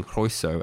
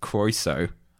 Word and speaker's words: Croeso: My other favourite thing going through the episodes was remembering how up until I think about episode 7 or 8 we were pronouncing Croeso as Croeso. – Yeah, well, until your mam told us that we were saying it wrong.